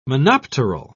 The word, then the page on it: monopteral: British English